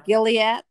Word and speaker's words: Gilliatt: This director for this movie was Sidney, I'm going to pronounce it probably wrong, but it's Gilliatt. – There you go. – Gilliatt.